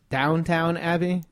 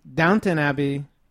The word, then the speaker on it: Downton Abbey: Because it's Downtown Abbey? That's what we keep saying is Downtown Abbey? – Because it's probably spelled differently. I've never seen it. – Downton Abbey. – Is spelled how? – Down T-O-N.